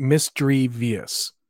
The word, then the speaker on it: Misdreavus: Mine is Misdreavus. I pronounce it Misdreavus. That's how I say it. How do you say it? Right? – Oh, it's Misdreavus? – Yeah. Because it's Misdreavus.